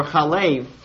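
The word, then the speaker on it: chalev (חֵלֶב): So this Hebrew word can be pronounced chalav, milk, or chalev, fat.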